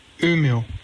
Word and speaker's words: Umeå: Umeå.